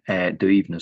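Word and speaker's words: Duibhne: Yeah, this is an ancestor figure called Duivne. Duivne, so you're bang on with your pronunciation there.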